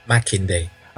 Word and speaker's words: Makinde: Go on, tell me again how it's pronounced. – Makinde.